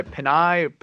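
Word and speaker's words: Penei: but I'm still calling him Penny Sewell. If those are three guys I'd be doing backflips for. I guess Malcolm was telling me he goes by Penai.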